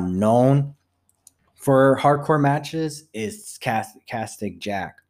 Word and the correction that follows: Cactus Jack: Cactus Jack.